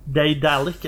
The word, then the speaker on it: Daedalic: Eh, det er Delic, Jeg har ingen anelse hvordan man uttaler det. Daedalic Entertainment.